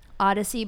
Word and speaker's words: Oddisee: Odyssey, but it's spelled Odd, O-D-D-I-S-E-E. Odyssey.